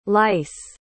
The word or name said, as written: leiss